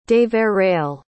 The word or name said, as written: deverell